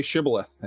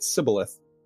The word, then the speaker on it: Shibboleth: If they they would ask them like, say Shibboleth, and if they because people the Ephraimites said it, they pronounced it Sibboleth, and uh, if yeah.